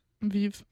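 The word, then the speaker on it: vivent: Et c'est ainsi que les hommes vivants. Is that close? Sorry. Vive.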